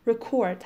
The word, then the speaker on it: record: باید ببینم این کلمه الان اینجا فعل هست یا اسم هست. وقتی فعله تلفظش ریکورد هستش.